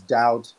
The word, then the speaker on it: doubt: It's similar to the words doubt, debt, and dumb. The B is silent because there's no being in those words. So for me, when I say N-O-T...